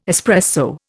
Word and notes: espresso: Indian

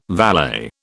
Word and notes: valet: British English

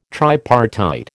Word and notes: tripartite: American English

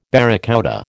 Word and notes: barracouta: American English

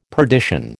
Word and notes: perdition: American English

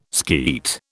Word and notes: skeet: British English

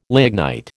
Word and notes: lignite: American English

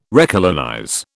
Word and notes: recolonize: British English

